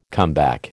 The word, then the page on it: comeback: American English